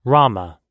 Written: Rama

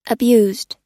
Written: abused

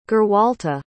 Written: gerwalta

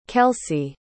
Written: kelsea

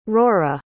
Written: rorah

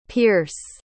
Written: pearce

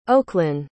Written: Oaklynn

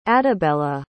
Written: adabella

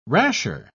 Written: rasher

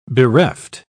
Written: bereft

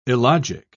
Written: illogic

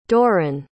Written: dorran

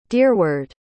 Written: deerward

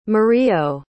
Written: murillo

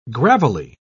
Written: gravelly

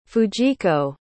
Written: fujiko

